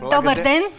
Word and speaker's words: Добър ден: How do you say Добър ден.